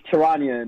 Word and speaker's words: Charania: It's Charania, man.